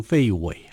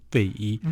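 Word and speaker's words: 费祎: 对，好，那我们看的就是这个呃，诸葛亮就秘密的跟长史杨仪，还有司马费禕，哈，很多人把它念成费禕，好，其实这个字读作禕，费禕，嗯哼，然后呃，这个护军叫姜维来去商讨他过世之后要怎么样去退兵，因为诸葛亮是。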